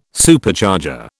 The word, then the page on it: Supercharger: British English